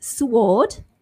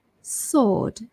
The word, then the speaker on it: sword: So you say D-E-T, basically, silent B. S-W-O-R-D, so it's not sword, but silent W, sword, sword.